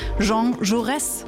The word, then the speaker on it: Jean Jaurès: Und jetzt möchte ich mich bedanken bei einem Hörer, der mir gesagt hat, dass ich den ganzen Morgen eine Straße falsch ausgesprochen habe. Jetzt mache ich es aber richtig. Jean Jaurès Straße nämlich.